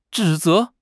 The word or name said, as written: 指责